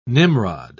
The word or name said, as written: nimrod